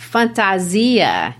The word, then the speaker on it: fantasia: If the inevitable cannot be avoided forever, perhaps the solution is to wear, and the word is pronounced fantasia in Portuguese. That's their Portuguese word for costume,